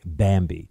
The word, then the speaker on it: Bambee: That's why I want you to try Bambi. It's spelled B-A-M-B-E-E.